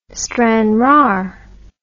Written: stranraer

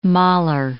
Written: Mahler

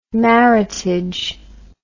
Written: maritage

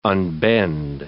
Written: unbend

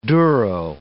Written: duro

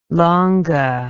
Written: longa

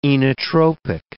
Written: inotropic

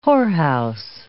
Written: whorehouse

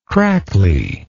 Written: crackly

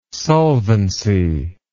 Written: solvency

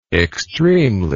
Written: extremely

